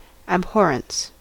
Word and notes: abhorrence: American English

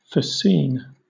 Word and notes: fascine: British English

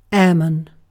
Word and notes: airman: British English

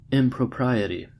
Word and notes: impropriety: American English

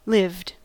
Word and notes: lived: American English

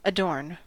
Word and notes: adorn: American English

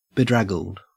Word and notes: bedraggled: Australian English

